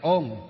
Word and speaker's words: ông: Nó không phải là ông đâu, nó là ông đấy, nhưng mà thì họ gọi là ôm, ô ngờ, ôm. Mình thì đọc là ô ngờ, ngờ ông. Bên kia là ô ngờ, ngờ ôm. Đấy là do cái phát âm của gì? Của bản địa, của từng địa phương.